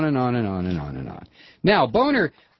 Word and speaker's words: Boehner: Boehner.